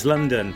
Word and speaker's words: LDN: Had a few hits, the follow-up that one to Smile LDN, pronounced as London.